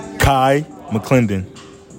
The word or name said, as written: KAI MCCLENDON